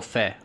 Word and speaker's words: Fe: Thing known as, I don't even know if it's supposed to be pronounced Fee or Fe. I'm going to guess Fe, because it's just spelled F-E.